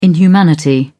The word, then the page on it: inhumanity: British English